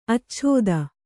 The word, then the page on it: ಆಚ್ಛೇದ: ācchēda